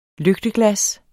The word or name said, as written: lygteglas